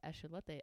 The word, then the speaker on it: axolotl: And so it's pronounced axolote, I'm being told.